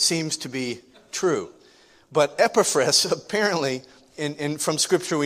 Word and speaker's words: Epaphras: And Epaphras is the pronunciation of his name today.